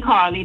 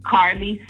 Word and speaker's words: Carle: My YouTube is Queen Carly. That's Queen Q-U-E-E-N Carly, C-A-R-L-E.